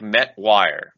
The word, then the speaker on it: Metoyer: Metoyer? I'm going to mispronounce this forever. Sorry. – Twyer. – Metoyer. Met. So it's like Metwire. Metwire.